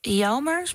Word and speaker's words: Jelmer: En wat ook bijzonder is, dat zijn zoon, die maakt ook muziek onder de naam van Jelmer. Spreek ik dat goed uit? – Ja, dat spreek je goed uit.